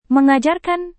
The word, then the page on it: mengajarkan: Indonesian